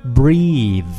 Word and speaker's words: breathe: 当动词来讲的话呢，它的发音是 breathe, breathe.